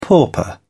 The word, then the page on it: pauper: British English